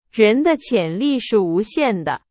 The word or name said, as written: 无限